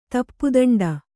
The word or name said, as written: ತಪ್ಪುದಂಡ